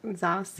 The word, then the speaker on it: Zacian: Zacian? There you go. – That's it. Yeah. – That's close enough. – We'll call that the correct pronunciation.